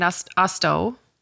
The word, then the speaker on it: Austo: And you can't just type in Austo. Is that how you pronounce it? – Yes.